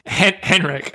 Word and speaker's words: Henrik: Henrik. I think you're the first person I've ever heard who's tried to pronounce that name, Henrik. Silent H.